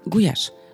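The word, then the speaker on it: gulyás: В изначальной форме венгерский гуляш произносится как «гуяш», что буквально переводится как «пастух» или «пастушка».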